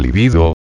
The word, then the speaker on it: libido: libido. Paréntesis. Término del latín. Paréntesis. Acentuado en la segunda sílaba.